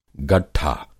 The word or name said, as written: गट्ठा